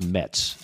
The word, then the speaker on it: Metz: It is pronounced Mets. So congratulations to last week's winner, Matt Metz of Irmo, who they used to call New York, like the Mets, when he was back in the Army.